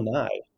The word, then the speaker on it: Nighy: Um, so it's pronounced Bill Nighy, apparently.